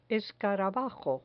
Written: escarabajo

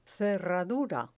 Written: cerradura

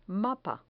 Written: mapa

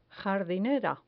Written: jardinera

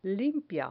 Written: limpia

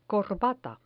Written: corbata

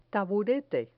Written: taburete

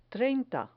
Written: treinta

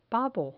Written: pavo

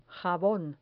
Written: jabon

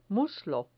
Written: muslo

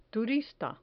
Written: turista

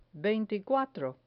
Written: veinticuatro

